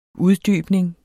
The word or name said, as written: uddybning